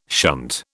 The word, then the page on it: shunt: British English